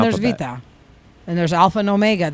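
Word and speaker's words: Vita (βήτα): Vita is the letter B. – Beta. It's beta with a B. – Yeah, beta. – Yeah, but vita is how you say it in Greek.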